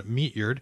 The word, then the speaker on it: Meatyard: Some of you may be familiar with the work of Ralph Eugene Meatyard, is spelled kind of like it sounds, Meatyard, and pronounced, as far as I've ever known, Meatyard.